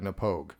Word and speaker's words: Knappogue: I thought it was Napogue. – Sorry, Napogue. I apologize. Napogue. I fucking looked it up on the internet just to make sure that I said it properly, and yet I still mispronounced it. – I don't know how it's pronounced. – No. You got it right. Napogue.